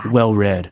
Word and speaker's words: well read: well read라고 했는데 그 read는요. read 있잖아요. 읽다. 그 단어의 pp 형태이기 때문에 발음을 read라고 한 겁니다. – well read.